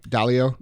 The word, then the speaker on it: Dalio: Dalio.